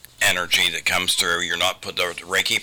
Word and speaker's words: Reiki: People, first, if they can even pronounce it, but Reiki is the correct pronunciation.